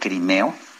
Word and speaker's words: Crimo: Dices que es eh, que, se, que se pronuncia crimeo. – Es correcto, sí, es, es uno de los apellidos raros, es como crimeo, pero se escribe crimo. Es Robert E. Crimo III, pero se pronuncia crimeo.